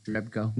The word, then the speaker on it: Jerebko: I always say his name wrong, but Jeregbo, right? Jeregbo. But Jerebko. – Yeah, Jerebko, whatever it is.